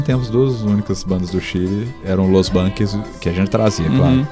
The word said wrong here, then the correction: Los Bunkers: Que a gente insiste pronunciar errado de acordo com eles, né? – Sim. – Los Bunkers.